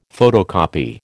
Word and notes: photocopy: American English

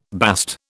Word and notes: bast: British English

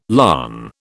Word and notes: larn: British English

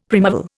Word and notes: primeval: Indian

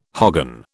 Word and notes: hogan: British English

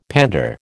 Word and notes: pander: American English

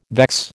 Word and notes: vex: American English

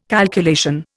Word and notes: calculation: Indian

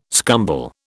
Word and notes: scumble: British English

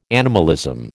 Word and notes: animalism: American English

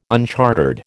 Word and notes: unchartered: American English

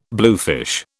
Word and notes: bluefish: British English

